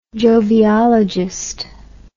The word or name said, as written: joviologist